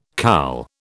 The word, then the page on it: CAL: British English